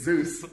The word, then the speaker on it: Zeus: And Pretzi, with possibly one of the weirdest things, yelling while someone's fighting, his last name is Zeus, it rhymes with booze.